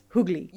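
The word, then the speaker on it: Huguely: It's H-U-G-U-E-L-Y, and I had to look up the pronunciation, and it's Hoogly. – U-G-L-Y.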